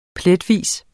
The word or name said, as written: pletvis